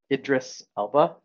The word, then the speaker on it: Idris Elba: That whole thing I thought that was very interesting and I I liked um, Idris Elba. Is that how you say his name? – Mm-hmm. More Idris.